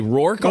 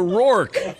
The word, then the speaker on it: Rourke: It's depends how you say it. A rock off. – No, it's not a rock off. – A rock off. – It would be a rock off. – You don't think Rourke sounds like talking? – No, it smells like it's like pork off. – You don't think Rourke sounds like talk? – What rhymes with no freaking clue? – You say rock off. Mickey Rourke.